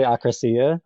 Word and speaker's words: akrasia: Is it akrasia? It's this gray area between free will. – Yeah, I've heard philosophy professors pronounce it all different ways, so I think you're fine. But I like to say akrasia.